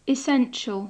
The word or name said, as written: essential